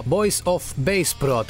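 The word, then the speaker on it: Voice of Baceprot: La banda de metal de Indonesia que se llama Voice of Bass Prot. Se escribe Voice of Base Prot.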